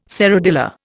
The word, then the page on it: serradilla: Indian